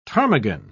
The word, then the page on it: ptarmigan: British English